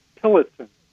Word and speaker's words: Tillotson: Alan, A-L-A-N, with four letters. And my last name is Tillotson. T as in Tom, I-L-L-O-T as in Tom, S-O-N.